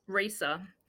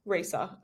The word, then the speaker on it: ReSa: It's ReSa. How do you say that? – ReSa. – ReSa. I thought so.